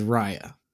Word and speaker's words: Raya: My name is Raya. – Yeah, so it's spelled R-A-Y-A, but pronounced R-I-Y-A, or maybe just R-Y-A. Or it's not Raya.